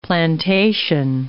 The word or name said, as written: plantation